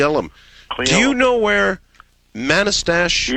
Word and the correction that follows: Manastash: Yeah, it's pronounced Manastash. – Figures.